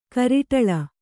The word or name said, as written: ಕಾರೊಡಲು